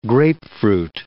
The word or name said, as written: grapefruit